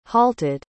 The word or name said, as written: halted